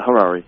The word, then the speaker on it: Harary: Yeah, Harari.